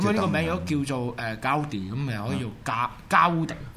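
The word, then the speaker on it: Gaudí: Gaudi 呢个系加泰隆尼亚文读音。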